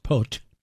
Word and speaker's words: Poot: the upbeat music of Marcel Michel Pot, spelled P-O-O-T, but pronounced Pot.